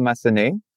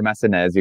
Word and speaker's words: Massenez: So it's French, so it's Emmanuel Massenez, or Massenez, you can say as well.